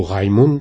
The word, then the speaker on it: Raymond: Mais je dois dire que ça m'a pris très très longtemps avant de me lancer dans ce livre sur Raymond de Miraval, que je voulais, ou Raymond, puisque on peut prononcer quand même à l'Occitane.